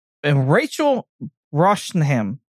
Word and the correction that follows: Brosnahan: Uh, Bra- Brosnahan. But yes, Brosnahan.